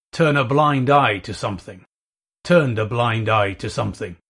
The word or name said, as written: turn a blind eye to